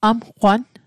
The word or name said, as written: amqwan